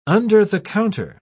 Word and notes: under-the-counter: British English

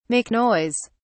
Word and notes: make noise: British English

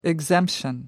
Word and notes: exemption: British English

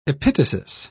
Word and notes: epitasis: British English

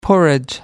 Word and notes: porridge: American English

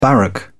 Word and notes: barrack: British English

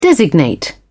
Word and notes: designate: British English

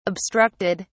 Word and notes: obstructed: British English

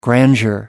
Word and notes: grandeur: American English

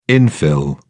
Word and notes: infill: British English

